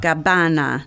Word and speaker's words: Gabbana: Gabbana.